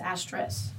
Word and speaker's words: asterisk: Fun fact, I thought it was asterisk.